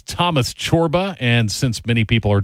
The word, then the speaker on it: Csorba: because it is C-S-O-R-B-A, pronounced Chorba.